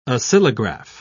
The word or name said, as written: oscillograph